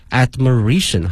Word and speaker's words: admiration: 同时啊，同学们注意一下它的发音，从原来的 admire 变成了 admiration 啊，重音发生了变化。